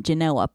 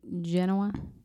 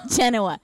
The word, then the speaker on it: Genoa: You mean Genoa? – Yes. Did I say it wrong? – Yeah. – I'm sorry. All right. You got me. – Ding, ding, ding, ding. – Genoa.